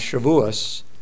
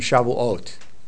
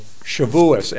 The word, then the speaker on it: Shavuos: So if you're talking to older Jewish people and you're talking about this holiday, they will recognize Shavuos with that pronunciation better than Shavuot.